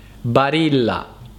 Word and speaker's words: Barilla: spesso in base alla lingua che è la vostra lingua madre viene pronunciata nel modo sbagliato, quindi ricordatevi che la pronuncia corretta è Barilla.